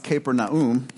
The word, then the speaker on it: Capernaum: When Jesus had entered Capernaum, or actually it would be pronounced Capernaum, a centurion came to him asking for help.